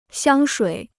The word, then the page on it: 香水: xiāng shuǐ